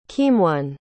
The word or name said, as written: keemeone